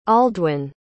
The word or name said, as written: aldwyn